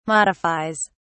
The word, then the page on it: modifies: British English